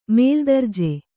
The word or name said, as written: ಮೇಲ್ದರ್ಜೆ